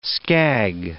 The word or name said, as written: scag